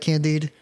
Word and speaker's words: Candide: Candide.